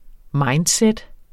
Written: mindset

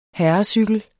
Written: herrecykel

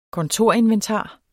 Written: kontorinventar

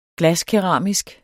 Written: glaskeramisk

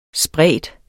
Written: spredt

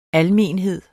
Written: almenhed

